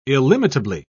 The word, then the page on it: illimitably: British English